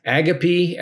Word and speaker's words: agape: Agape, as the British pronounce it, or agape, is divine love, unconditional love.